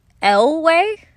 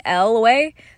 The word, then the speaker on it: Eyllwe: It's Elway, E-Y-L-L-W-E, Elway.